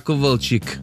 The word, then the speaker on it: Kowalczyk: Justina Kowalczyk Er det sånn du uttaler den av det? Ikke det Kowalczyk? – Justina Kowalczyk ja. Jeg tror det chick